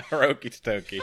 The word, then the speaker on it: Hiroki Totoki: Chief Financial Officer Hiroki Totoki. Did I say that right? – I think so, yeah. Hiroki Totoki. That's correct.